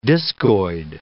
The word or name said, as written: discoid